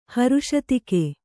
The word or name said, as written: ಹರುಷತಿಕೆ